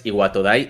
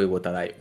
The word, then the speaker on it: Iwatodai: creo que es Iwatodai y creo que digo Iwotadai, una de las dos.